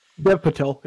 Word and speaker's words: Dev Patel: Dev Patel.